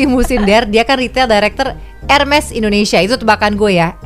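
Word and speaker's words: Hermès: So, it's a silent H sama S-nya kesebut. Hermes. – Hermes.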